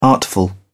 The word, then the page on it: artful: British English